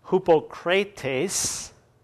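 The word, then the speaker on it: hypokrites: The Greek word for the English word that we translate into hypocrites, the Greek word looks a whole lot like it, only it's pronounced hypokrates, and it means a stage actor, hence one who pretends to be what he is not.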